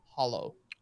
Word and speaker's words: Halo: And his answer to the question is Halo is actually pronounced hollow.